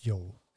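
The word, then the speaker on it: Yeoh: Sonst würde ich sie jetzt immer die Person, die dargestellt wird von Michelle Yao nennen. – Aber Michelle ist, Yo sagt man Yo. einfach nur. Ja, ja, es ist Yo.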